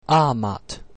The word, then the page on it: amat: Latin